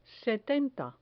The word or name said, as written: setenta